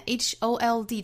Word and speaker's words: hold: 那我们来看 HOLD 这个词中间还有字母 L, 这个单词里这个 L 是要发音的发舌侧音 L, 所以是 hold, hold.